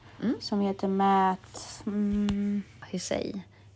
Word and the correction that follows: Hussey: Jag vet inte hur, hur de uttalar men, ja. – Matt. Matthew Hussey.